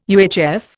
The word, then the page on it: UHF: Indian